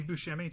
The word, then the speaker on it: Buscemi: Steve Buscemi. Did I say it right? Why did I second guess that? I'm very tired. Uh, you're you're good.